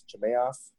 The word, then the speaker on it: Chimaev: I'm not the greatest at pronouncing names, but Chimaev, is that what it is? Chimaev? – Yep, Chimaev.